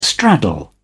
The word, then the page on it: straddle: British English